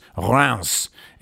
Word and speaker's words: Reims: It's Reims, except the I is the same nasal vowel as the first syllable of Guingamp.